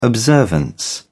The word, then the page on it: observance: British English